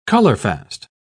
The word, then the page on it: colorfast: British English